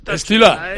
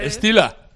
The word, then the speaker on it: Stieler: No, Stieler. La E no se pronuncia, ¿verdad? – Stila, Stila. Estila.